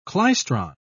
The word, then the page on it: klystron: British English